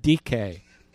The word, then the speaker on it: dike: This is the word dike, spelled like dike, D-I-K-E, dike.